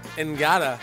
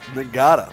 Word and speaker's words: Ngata: Haloti Ngata. The G is silent. – Typically pronounced what? – Ngata. – Ngata.